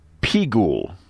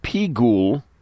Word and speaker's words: Pigul: It was about Pigul. Pigul, Peyud Gimel Lamed, Pigul, P-I-G-U-L.